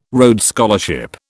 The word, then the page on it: Rhodes Scholarship: British English